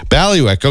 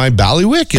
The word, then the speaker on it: bailiwick: B-A-I-L-I-W-I-C-K. – Okay. – So like Bally and then Wick. – Ballywick. Okay. So, doing radio is my Ballywick.